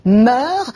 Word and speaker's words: mœurs: Même si mœurs est un nom féminin, pluriel, invariable, on doit dire mœurs. Moi, j'aime pas ça. Ça me fait penser au verbe mourir. Je meurs, tu meurs. Non, la prononciation mœurs est peut-être incorrecte, mais en tout cas, elle est... entrée dans l'usage, hein,